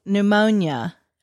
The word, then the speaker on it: pneumonia: They're like pneumonia. – Oh, the silent letters? – Yeah. – Yes. – Pneumonia has a P on the front. – Yes, it does.